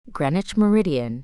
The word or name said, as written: Greenwich Meridian